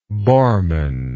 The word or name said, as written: Bormann